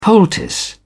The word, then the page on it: poultice: British English